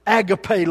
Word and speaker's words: agape: The kind of love that God has for us, that agape love, as our last president before said, agape love, Brother William, Bill. Agape, he didn't even know how to pronounce the word. Agape.